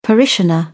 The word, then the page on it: parishioner: British English